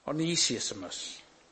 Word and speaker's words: Onesimus: Now, he was credited with founding the church, and one famous slave, Onesius. I don't know if that was pronounced right. Every time I try and pronounce this, I get a different pronunciation. Onesimus, that's it.